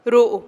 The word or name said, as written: ru'u